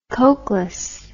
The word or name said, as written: koklass